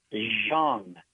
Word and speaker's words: Jeong: That would be Zhong. J-E-O-N-G. – Correct, and great day in the morning. – That is correct.